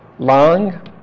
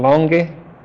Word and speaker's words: Lange: Lang or Lange, German pronunciation, Lange, a driver, all agree.